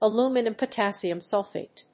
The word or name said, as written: aluminum potassium sulfate